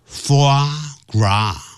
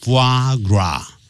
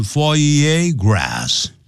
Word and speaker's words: foie gras: 好，这里都有几个法语啊，法文。好，一开头讲 foie gras 啊，foie gras，那不要念成 foie gras 啊，它这是固定的，它就是念成的啊，foie gras 啊，foie gras。这个鹅肝是什么东西呢？啊，怕你不懂，所以用英文解释：is fattened，